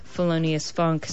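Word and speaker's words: Phelonious Phunk: You can also keep up with me on Twitter at Felonious Funk, spelled with P-H's, not F's. Felonious Funk.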